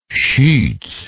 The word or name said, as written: schutz